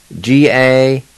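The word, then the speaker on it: gadohi: And the first word for today is on foot, and it's spelled E-L-A-D-I. E-L-A-D-I. Number two is on ground, or on the ground. And it's spelled G-A-D-O-H-I. G-A-D-O-H-I. Number three, on top. And it's spelled G-A-D-U. G-A-D-U.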